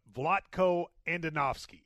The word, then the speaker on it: Vlatko Andonovski: I'm going to butcher his name, but Vlatko Andonovski, I believe, is the coach's name. He said, did I get that right? – I think you did.